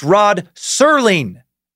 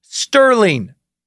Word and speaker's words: Serling: Sci-fi sucker David Thornberry writes, It's Rod Serling, not Sterling.